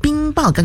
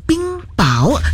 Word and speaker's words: 冰雹: 那在刚刚的上一个小时的六点钟的整点新闻当中呢，就是有两点要让你来留意的，就是一个呢就是这个读音方面，就是冰爆，刚刚我念的是冰爆，但其实真正的读音刚刚就搜寻了，也谢谢我们的前辈来提醒，是说这个冰爆原来正确的读音是叫做冰雹，这个第二个字呢是第二音的，所以呢在这个拼音上呢也要稍微的留意了。之前的这个读法呢一直以来其实真的是念错了。